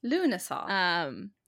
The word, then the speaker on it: Lughnasadh: Okay, so Lunessa, this is where I had my pronunciation guide, so I know how to say it again, spelled L-U-G-H-N-A-S-A-D-H. Lots of consonants. – Lunessa. – Yeah, Lunessa.